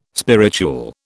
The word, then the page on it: spirituel: British English